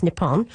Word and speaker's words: Nippon: The chant uses the formal pronunciation of Japan, which is Nippon, but then it has a twist pulled from Spanish language chants from other countries.